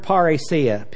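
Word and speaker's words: parrhesia: Boldness is the word parousia. P-A-R-R, long E-S-I-A. Parousia.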